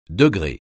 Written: degré

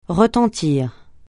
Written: retentir

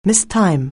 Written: mistime

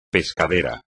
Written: pescadero

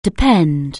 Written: depend

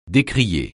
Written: décrier

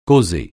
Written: causer

